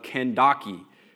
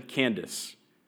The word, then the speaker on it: Kandake: So he started out, and on his way, he met an Ethiopian eunuch, an important official in charge of the treasury of Kandake or if you want to mispronounce that correctly, like an American, Candace, Kandake, Candace, which means queen of the Ethiopians.